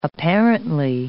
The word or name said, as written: apparently